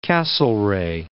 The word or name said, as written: Castlereagh